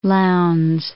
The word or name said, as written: Lowndes